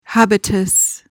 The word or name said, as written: habitus